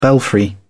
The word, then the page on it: belfry: British English